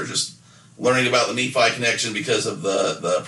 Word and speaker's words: Nephi: but it is Nephi.